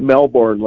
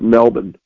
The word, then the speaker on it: Melbourne: Then he's in, as I believe they pronounce it, all or Melbourne. It's not Melbourne like we would pronounce it. Apparently it's pronounced Melbourne.